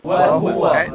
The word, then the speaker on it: وهو: قراءة حفص تقول وهو بضم الهاء وهو تتنطق ازاي؟ – وهو